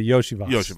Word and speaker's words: Iosivas: Do we have a pronunciation guide on Charlie Jones? Yeah, Iosivas. – Which says, obviously, Yoshi Voss.